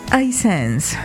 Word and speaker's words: Hisense: ¿Cómo es que se pronuncia esa marca? – Hisense. – Ah, Hisense. – Hisense. – Buena. – O Hisense, como okay.